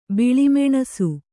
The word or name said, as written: ಬಿಳಿಮೆಣಸು